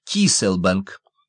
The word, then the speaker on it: Кейселбанк: Здесь воды Гольфстрима огибают самую южную точку Соединенных Штатов, и тут же, подобно островку безопасности, на пересечении 2 магистралей лежит треугольник Кейселбанк, который произносится как Киселбанк.